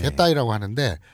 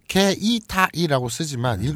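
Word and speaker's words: 게타이라고 하는데 (携帯): 게타이라고 하는데 게이타이라고 쓰지만 음. 읽을 때는 게타이라고 하죠.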